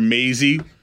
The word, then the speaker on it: Maisie: I guess I'm seeing, you know, and then the fact that they called her Maisie rhymes with lazy.